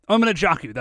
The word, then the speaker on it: Amanojaku: And they don't tell us this immediately, but his name is Amanojutsu, right? – Amanojaku. – Am I pronouncing that correctly? Amanojaku, that's right.